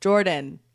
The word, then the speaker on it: Jordan: And I'm like, Jordan, like the river.